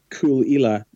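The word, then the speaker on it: Caol Ila: Personally, I would go back to Islay for a whiskey called Cool Ila, which is spelt C-A-O-L. – C-A-O-L, yeah. – Yes. And then new word, I-L-A. – L-A, cool Ila. So, all right, C-A-O-L.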